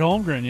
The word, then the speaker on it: Holmgren: Chet Holmgren, yeah.